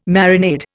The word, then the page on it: marinade: Indian